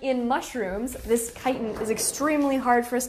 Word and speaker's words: chitin: Chitin. – And a lot of you might think that we would pronounce it chitin, but it's actually pronounced chitin with a K.